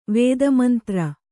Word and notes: ವೇದಮಂತ್ರ: Kannada

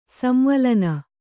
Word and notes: ಸಂವಲನ: Kannada

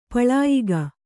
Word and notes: ಪಳಯಿಗೆ: paḷayige; Kannada

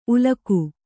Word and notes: ಉಲಕು: ulaku

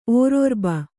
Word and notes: ಓರೊರ್ಬ: kannada

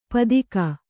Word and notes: ಪತ್ತಿಗ: Kannada

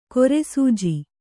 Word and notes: ಕಿರುಸೆಜ್ಜೆ: kiru sejje

